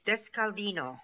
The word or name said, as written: Deskaldino